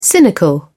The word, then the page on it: cynical: British English